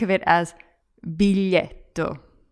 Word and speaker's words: biglietto: And a lot of people make the mistake of saying biglietto with the English word big at the beginning, so pronouncing the g. But in the middle, that kind of weird l sound, spelt with a gl, is kind of like a cross between a y and an l.